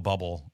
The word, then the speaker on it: Bublé: And the same goes with Michael Bublé, or Bublé.